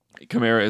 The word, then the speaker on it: Kamara: He did say it kind of depends on how it rolls off the tongue, so some people say Kamara, some people say Kamara. He didn't seem to mind that much, but Kamara is the way he pronounces it, so that's the way that I will pronounce it.